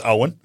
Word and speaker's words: Eoin: I also love the male name Owen, but it's spelled E-O-I-N. – Oh, like the cricket player? – Yeah. So you look and you go, Owen.